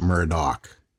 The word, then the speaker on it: Murdaugh: Murdoch is spelled M-U-R-D-A-U-G-H, but I think it's pronounced Murdoch. And Alex, A-L-E-X, is pronounced Alec.